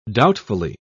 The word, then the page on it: doubtfully: British English